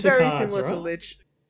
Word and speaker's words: Lich: It's kind of like upgraded Lich. Or Lick. I don't know how it's pronounced. – I think it's Lich. – Okay. – Definitely Lich.